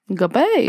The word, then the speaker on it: Guhppy: At least I think that's how it said, G-U-H-P-P-Y. – Guppy. – But I think the H is silent.